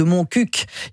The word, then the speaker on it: Montcuq: Dans le Petit Rapporteur, voilà ce reportage sur le village de Montcuq. Il faut prononcer le cul à la fin. – Oui, comme ils disent, on dit un coq, on ne dit pas un co, donc on dit mon coq.